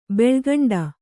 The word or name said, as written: ಬೆಳಿಕೊಂಡೆ